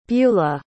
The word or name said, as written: bulah